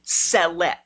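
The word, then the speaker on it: Ceh'let: But amidst this, we get to see the command structure at least briefly within the Rakatans, because there is a sub-praetor named Celet.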